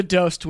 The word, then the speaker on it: DOST: D-O-S-T? – Yes.